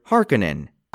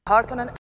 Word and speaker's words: Harkonnen: Personally, I found myself distracted by the pronunciation of the word Harkonnen. – Harkonnen. – Harkonnen. – Harkonnen. – Which was different than the original movie's version, Harkonnen. – Baron Harkonnen. – Baron Vladimir Harkonnen! – There is a Harkonnen among you. – This is a Harkonnen animal. – Since I didn't know until very recently that Harkonnen is the correct pronunciation, I thought that the Sci-Fi miniseries was making a giant mistake.